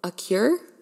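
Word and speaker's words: Acure: But I then follow up with the, I don't even know how to say this, I think it's like Accutane actually now that I read it, it's a cure. It's probably just a cure, like if you think of a medicine cure, that's how it's spelled.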